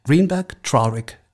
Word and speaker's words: Greenberg Traurig: Du bist Arbeitsrechtlerin bei, ich muss das richtig aussprechen, Greenberg Traurig, ist das richtig? – Genau so, ja. Man möchte das Deutsch aussprechen, aber dann klingt es falsch.